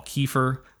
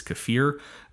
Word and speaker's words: kefir: which you might call kefir. I learned that I was pronouncing that wrong. It is kefir, or a mixture of yogurt and water.